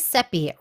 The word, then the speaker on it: Sepi: Hi, my name is Seppi. Rhymes with Peppy is what I tell people.